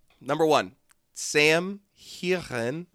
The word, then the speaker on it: Heughan: Sam Heughan. – Oh yeah, baby. Heughan.